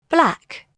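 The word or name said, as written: black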